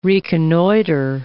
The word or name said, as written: reconnoitre